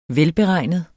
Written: velberegnet

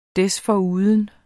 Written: desforuden

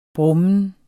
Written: brummen

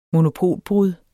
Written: monopolbrud

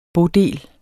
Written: bodel

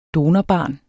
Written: donorbarn

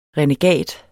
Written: renegat

